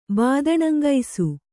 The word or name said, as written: ಬಾದಣಂಗೈಸು